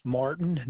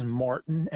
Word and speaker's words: Martin: Yes, my name is Nicholas Martin. Nicholas is spelled N-I-C-K-O-L-A-S. The last name is Martin, M-A-R-T-I-N.